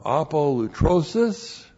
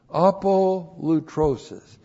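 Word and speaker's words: apolutrosis (ἀπολύτρωσις): Now, apolutrosis, looks like that in the Greek, that's A-P-O-L-U-T-R, long O-S-I-S, apolutrosis.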